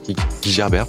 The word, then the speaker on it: Guy Gerber: Clarion et Guy Gerber, je sais pas comment on prononce, Guy, Gerber peut-être Je crois que c'est Guy Gerber, je crois, à l'anglaise.